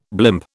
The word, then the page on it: blimp: British English